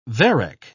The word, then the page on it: varec: British English